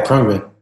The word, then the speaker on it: Khruangbin: So you guys still released, by the way, everyone listening, this is DJ Johnson from the band Khruangbin. Did I say it right? – Yeah, Khruangbin.